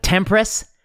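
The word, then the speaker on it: TMPRSS2: Like Tempress, which apparently is how you pronounce TMPSSR2 or whatever that receptor was, that polymorphism.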